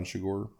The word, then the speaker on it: Chigurh: At that point, Anton Chigurh, who's the Javier Bardem portrays Anton Chigurh, to say it correctly, who's a presumed mob assassin / serial killer, gets on his trail.